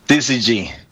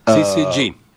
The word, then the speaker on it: syzygy: Syzygy. – Syzygy.